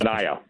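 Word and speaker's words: Manaea: Manea. – Okay. – Manea. Like a papaya.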